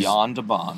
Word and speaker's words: Jan de Bont: Well, the director, to place some blame, the director is Jan de Bont. I believe I'm pronouncing that correctly. – He's, Jan de Bont.